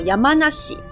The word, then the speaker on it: 山梨: 那山梨的日文念作 Yamanashi，那它是日本最大的水果产地。